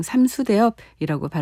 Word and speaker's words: 삼수대엽: 생각하기 때문에 삼삭대엽 이렇게 발음 안 하고 그냥 삼수대엽이라고 발음하셔서 저도 삼수대엽이라 발음합니다.